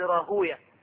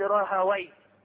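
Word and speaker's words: راهويه: ذهب ابن حزم وإسحاق بن راهوية، والمحدثون ينطقونها هكذا، يقولون إسحاق بن راهوية، وغيرهم يقول إسحاق بن راهاوي.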